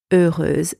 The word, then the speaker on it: heureuse: Instead of the silent X, we have S-E pronounced Z, Z, heureuse.